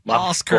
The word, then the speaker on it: Oscar: Ajá, ¿y cómo lo pronuncian mi nombre, Oscar, los americanos? – Oscar. Oscar. – Oscar. – Ask her.